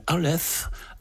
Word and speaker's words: Aleph: Ça s'appelle Aleph. A-L-E-P-H, c'est bien ainsi qu'on le prononce, cher Alain ? – Oui. – Oui, c'est bien ça.